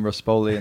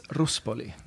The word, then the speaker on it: Ruspoli: And no, people mispronounce the name all the time, Ruspoli and stuff, but it's Ruspoli.